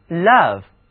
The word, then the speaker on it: love: Love 果然就是从心底发出的爱。字母 O 读作三角啊，而字母 V 读作 V。你们把这个词的音标写下来看一下，一个正着的三角，一个倒着的三角，是不是很有意思呢？